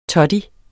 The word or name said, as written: toddy